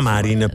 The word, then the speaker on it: Marin: La Marin, per intenderci. – Esatto, la Marin. – Sì. Non le Marlin, la Marlin, esatto. – Senza la R. – Sanna Marin. – Senza la L, scusate.